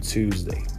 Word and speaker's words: Tuesday: Lembra a pronúncia do número 2, two. Tuesday.